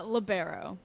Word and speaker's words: libero: I want to know, is it pronounced libero or libero? – Or liberal. – Libero. – It's libero.